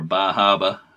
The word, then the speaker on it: Bar Harbor: Harbor Whale Watch or Bahaba, as some people pronounce it.